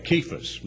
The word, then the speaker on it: Cephas: And that he was seen of Cephas, it should be pronounced that way, Cephas, meaning Peter, then of the 12, afterward of the 12.